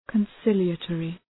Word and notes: conciliatory: {kən’sılıə,tɔ:rı}

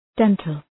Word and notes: dental: ‘dentəl

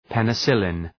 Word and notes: penicillin: ,penı’sılın

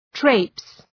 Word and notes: traipse: treıps

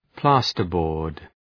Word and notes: plasterboard: ‘plæstər,bɔ:rd

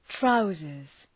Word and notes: trousers: ‘traʋzərz

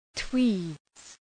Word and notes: tweeds: twi:dz

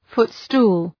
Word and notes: footstool: {‘fʋt,stu:l}

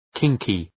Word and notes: kinky: ‘kıŋkı

